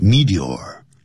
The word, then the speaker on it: meteor: 但是流逝的那种流星呢，那么我们已经说过叫 meteor,M-E-T-E-O-R, 有两个发音 ,meteor 或是 meteor, 两个发音都可以。